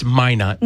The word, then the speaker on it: Minot: Because I was just waiting to hear one pronunciation. It's Minot.